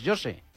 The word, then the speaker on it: José: Pero José, en portugués, José.